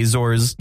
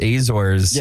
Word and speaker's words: Azores: Which is happening in Portugal in the Azores.